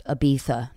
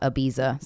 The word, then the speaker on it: Ibiza: And I just also learned from one of your emails that it's pronounced Ibiza, not Ibiza.